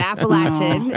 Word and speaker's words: Appalachian: She's at Appalachia State. Did I say that right? – You were really close. It's Appalachian. And it's okay.